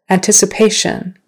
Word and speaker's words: anticipation: Anticipation. Anticipation. Now the accent is on the third syllable. Anticipation.